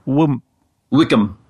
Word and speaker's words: Wycombe: High Wycombe, Wycombe. Maybe you can visit there, when you're over there. – Sure. It's probably pronounced like Wickham. High Wycombe.